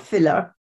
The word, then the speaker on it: Villach: Well, the town is called Villach, spelled with a V, but pronounced as F, Villach.